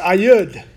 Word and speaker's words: Ehud: And the Lord raised them up a deliverer, Ehud and it, it's actually pronounced Ayud,